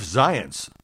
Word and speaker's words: Zients: Who is Jeff Zients? Well, I now got the pronunciation right. It rhymes with science, somebody put in their story.